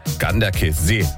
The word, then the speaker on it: Ganderkesee: Herzlichen Dank nochmal für die Aussprachetipps, die schnell reinkamen von unserem Radioarzt Jürgen in Goch, der mir gleich geschrieben hat, Ganderkesee wird das ausgesprochen.